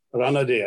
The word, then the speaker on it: Ranade: Just as I mentioned, Justice Ranade earlier, Ranade, I think is the right pronunciation, who had a social justice movement, a social conference, which is also a reformist thing.